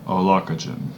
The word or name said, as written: Aulacogen